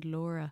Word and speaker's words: Laura: Well, I got this the other day when I ordered a drink in Starbucks, and I said Laura, and I pronounce it real like L-O-R-A, and that's what most people tend to write.